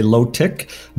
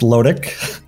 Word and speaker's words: Lotic: We, as a Canadian, we have a lot of Canadians who say Lotic, but it's pronounced Lodic.